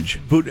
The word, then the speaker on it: Buttigieg: All right, my friends, it looks like South Bend, Indiana Mayor Pete Buttigieg, is that how we're pronouncing Buttigieg. – It? Okay.